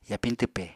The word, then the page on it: yapiñ twpe: Apatani